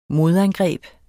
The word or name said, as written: modangreb